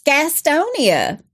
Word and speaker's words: Gastonia: And when living in North Carolina, that's how you say it. You don't say Gastonia. – It's Gastonia.